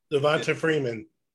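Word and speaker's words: Devonta Freeman: Am I saying his name right? – Devontae Freeman.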